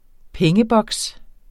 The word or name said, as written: pengeboks